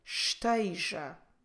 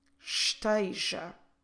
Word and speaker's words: esteja: We have the verb form esteja. Esteja. Como se escreve esteja? E-S-T-E-J-A. Esteja.